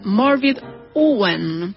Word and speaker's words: Morfydd Owen: En realidad se pronuncia Morvid Owen, pero se escribe Morvid Owen. Morfydd Owen.